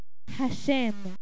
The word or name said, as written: haSheim